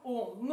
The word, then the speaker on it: Ом: И вот это Ом, оно состоит из трех букв А, У, М, да? Но ну, не то, что А, а такое более смягченное, это что-то А между О, да?